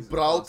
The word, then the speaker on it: Braut: Erling Braut Haaland.